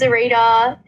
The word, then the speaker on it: Xirita: and the other thing is, my artist name is Xirita. It's spelled with an X.